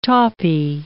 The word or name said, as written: tawpie